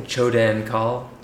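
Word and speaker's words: Choedan Kal: Using the Chodian call? How do you say that? – I'm saying Chodan call. – Chodan call? – Chodan call? – Yeah. What's wrong with that? – There's nothing wrong with that.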